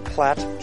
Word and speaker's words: Platt: A-L-E-N. – Where are you from? – From Slovenia. – What's your name? – Platt Johnson. – Platt, can you spell that? – P-L-A-T-T.